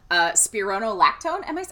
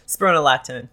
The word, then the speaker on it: spironolactone: spironolactone. How do you say that? – Spironolactone, yes.